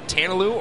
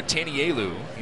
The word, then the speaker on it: Tanalu: Tanalu's last name can be pronounced Tanalu or Tanielu. He said the English version is Tanalu, and that's what he prefers. But in his family, it's Tanielu.